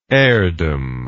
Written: heirdom

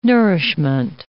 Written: nourishment

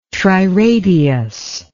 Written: triradius

